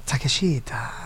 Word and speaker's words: Takeshita: Japanese take is not pronounced like the English word take. It's take. Takeshita.